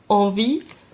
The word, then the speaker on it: Envie: Ganz schlechtes Französisch meinerseits, deshalb der Champagner heißt, ich hoffe, ich spreche es jetzt richtig aus, Envie. Linda hebt den Daumen, das war richtig ausgesprochen.